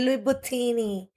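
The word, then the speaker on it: Louis Vuitton: y que de momento tú vayas a la, a la Louis No se dice así, es Luis Buttini.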